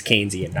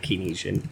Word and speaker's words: Keynesian: It's Keynesian, not Keynesian.